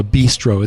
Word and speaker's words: Beastro: That owns a technology called a bistro. It's spelled B-E-A-S-T-R-O.